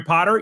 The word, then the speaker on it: Potter: My name is Jerry Potter. Yes, it rhymes with the boy wizard,